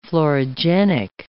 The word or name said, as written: florigenic